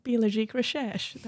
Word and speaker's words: Biologique Recherche: So if you say that with a French accent, Biologique Recherche, that's how you say it.